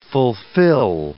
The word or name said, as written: fulfil